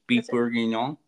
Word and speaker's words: beef bourguignon: I would also like to try some French food, if I pronounce it right, sometimes my mouth doesn't pronounce things, beef bourguignon.